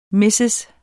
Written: mrs.

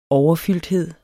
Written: overfylde

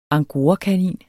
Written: angorakanin

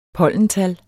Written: pollental

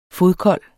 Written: fodkold

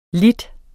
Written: li

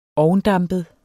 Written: ovndampet

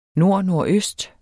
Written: nordnordøst

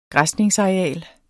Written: græsningsareal

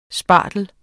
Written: spartel

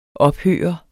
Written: ophøre

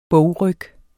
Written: bogryg

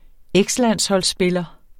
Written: ekslandsholdsspiller

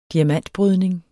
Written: diamantbrydning

